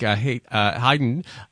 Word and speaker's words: Haydn: This is, I think, Haydn String Quartet 71, number 2, movement 1.